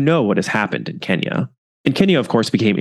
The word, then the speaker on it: Kenya: They are all out in Kenya, or Kenya, as I feel certain they would have pronounced it.